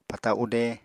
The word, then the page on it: pata ude: Apatani